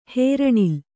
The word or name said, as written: ಹೇರಣಿಲೆ